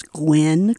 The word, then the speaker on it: Gwenn: My name is Gwen Craig, and if it's spelled later, it's G-W-E-N-N, Craig, C-R-A-I-G.